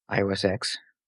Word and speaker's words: iOS X: iOS X. – Still pronounced 10. It's not like the X-Men.